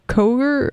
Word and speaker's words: Koger: So The Pines, I think they played at Koger. I don't know how to say it. It sounds like Kroger, but it's not. It's Koger.